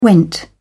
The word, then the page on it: went: British English